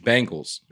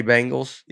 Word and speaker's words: Bengals: That's how you say Cincinnati Bengals? – Yeah. – Since I actually sided with him in the Penguin pronunciation, I find it funny to hear it pronounced Bengals as if it rhymes with Pringles.